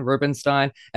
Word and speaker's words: Rubinstein: That's Spencer the Broadway Spy, and we are joined by Tony Award winner John Rubinstein. And I am saying that right. – Yes, thank you for saying it right. People very often mispronounce it, but no, you got it right.